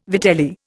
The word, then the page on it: vitelli: Indian